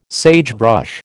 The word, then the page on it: sagebrush: American English